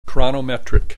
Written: chronometric

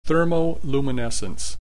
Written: thermoluminescence

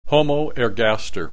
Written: Homo ergaster

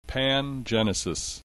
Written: pangenesis

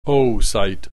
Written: oocyte

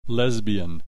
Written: lesbian

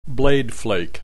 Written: blade flake

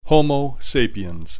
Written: Homo sapiens

